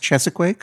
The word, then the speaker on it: Cheesequake: It is spelled Cheesequake. – It's spelled Cheesequake like there's something you can get in the Guy Fieri restaurant. – But you're telling me that it's actually pronounced Chesequake? – That's what I was told.